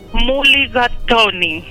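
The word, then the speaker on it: Mulligatawny: Muligatoni se escribe M-U-L-L-I-G-A. – T-A-W-N-Y.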